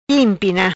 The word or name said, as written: Impina